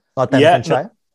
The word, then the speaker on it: Northamptonshire: Is it the pronunciation Northamptonshire? – Northamptonshire. Shea. Yeah, go up a bit.